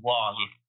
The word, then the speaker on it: Wang: Again, like I said, like she said, her name is spelled Lulu Wang, and Lulu Wong is how it's pronounced.